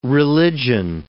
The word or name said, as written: religion